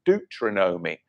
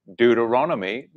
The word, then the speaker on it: Deuteronomy: When I first became a believer with my English accent, I was Deuteronomy, but I've now come to be able to pronounce it properly, Deuteronomy, not Deuteronomy, but back in 1996, I'd never heard of it before. So that's how I used to pronounce it.